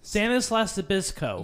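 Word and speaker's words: Stanislaus Zbyszko: Antonio Inoki, Statinelius Zabisco. I butchered that, but whatever. – Stanislas Zabisco.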